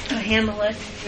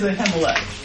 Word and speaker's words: Ahimelech: How do you say that name? – Ahimelech. – It is Ahimelech. – Ahimelech. You make the H sound.